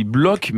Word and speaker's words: Bloch: On dit Bloch, même si ça s'écrit Bloch, hein, B-L-O-C-H.